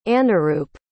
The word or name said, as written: anuroop